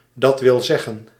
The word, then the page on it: azaz: /ˈɒzɒz/